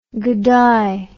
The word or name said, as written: gidday